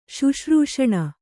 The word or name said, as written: ಶುಶ್ರೂಷಣ